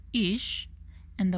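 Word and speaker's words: isha (אשה): In Hebrew, the word for fire is that Hebrew letter pronounced as esh, while the word for man pronounced as ish, and the word for woman is pronounced as isha.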